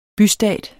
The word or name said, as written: bystat